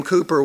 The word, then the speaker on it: Cowper: It looks like Cowper, but it's pronounced Cooper.